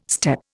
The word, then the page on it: step: Indian